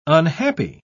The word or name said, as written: unhappy